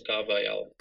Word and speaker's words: Carvalhal: Carlos Carvalhal.